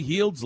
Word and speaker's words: Heald: Buddy Heald's last name was one syllable. Heald.